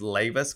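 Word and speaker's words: Leiva: So our website is Leyva's Coffee, and Leyva's is my last name is Leyva, and it's L-E-I-V-A, and it's pronounced like laying back, Leyva's Coffee.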